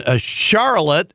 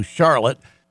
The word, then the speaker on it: Charlotte: She tried to escape to North Carolina for Thanksgiving, but we collared her in Charlotte. That's not Charlotte. That's how we pronounce it in Michigan, but they pronounce it maybe the way most people do, Charlotte.